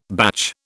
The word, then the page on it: batch: British English